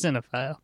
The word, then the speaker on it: cinephile: Cinemaphile. – Yeah, it's pronounced cinephile.